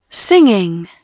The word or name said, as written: singing